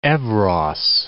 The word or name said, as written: evros